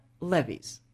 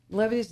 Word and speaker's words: Levi's: That in English are called Levi's, not Levi's. Levi's doesn't exist, no?